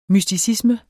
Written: mysticisme

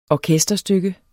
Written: orkesterstykke

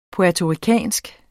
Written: puertoricansk

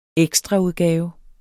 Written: EKSTRAUDGAVE

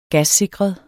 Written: gassikret

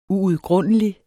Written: UUDGRUNDELIGT

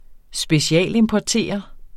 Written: specialimportere

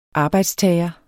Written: arbejdstager

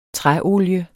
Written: træolie

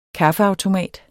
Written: kaffeautomat

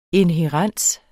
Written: inhærens

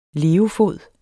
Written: levefod

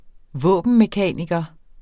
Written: våbenmekaniker

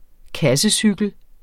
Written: kassecykel

